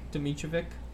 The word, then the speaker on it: Dimitrijevic: That's how you say it in English. In Serbian, it's it's a lot different, but in English, it's just Dimitrovic. – Dimitrovic.